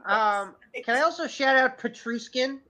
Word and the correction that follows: Nichushkin: Petrushkin Val Nichushkin.